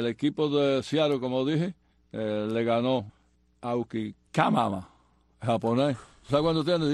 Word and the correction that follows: Iwakuma: Bueno, primero pronúncialo bien. – Ok. Ok. Ok, el ganador fue Aukama, el japonés. – Ay, Dios mío. – Tiene 13-6 y tiene 2.76 de efectividad, quinto. – Iwakuma se llama el japonés.